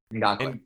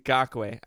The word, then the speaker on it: Ngakoue: and we got Yannick Ngu- Ngukwe. That was Ngakoue. – Ngakoue.